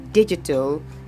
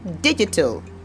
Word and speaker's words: digital: So for this word, digital, it's the soft G sound you make use of in the word. So you say digital, digital, and it's in this.